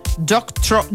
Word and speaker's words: Doctorow: Così è stata ribattezzata da un giornalista ed esperto di media canadese, Cory Doctorow. Doctorow. Ho detto bene.